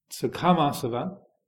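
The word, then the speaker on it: kāmāsava: So then, kamasava is the first one. K long A-M-A, kamasava.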